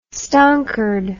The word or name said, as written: stonkered